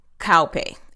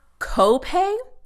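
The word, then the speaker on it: kaupe: so you pronounce it cowpay. It's not copay like they've been saying. K A U makes a cow sound, okay?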